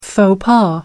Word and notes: faux pas: American English

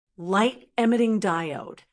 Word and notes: light emmiting diode: American English

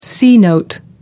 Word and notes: c-note: American English

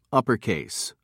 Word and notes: uppercase: American English

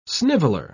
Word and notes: sniveller: British English

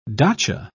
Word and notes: dacha: British English

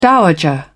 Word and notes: dowager: British English

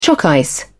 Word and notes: choc ice: British English

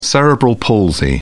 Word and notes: cerebral palsy: American English

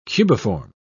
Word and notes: cubiform: British English

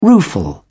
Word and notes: rueful: British English

